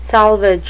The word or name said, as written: salvage